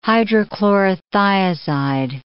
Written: hydrochlorothiazide

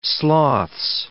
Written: sloths

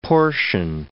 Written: portion

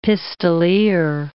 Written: pistoleer

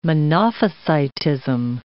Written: Monophysitism

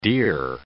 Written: deer

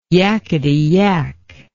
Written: yakety yak

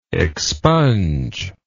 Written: expunger

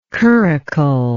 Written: curricle

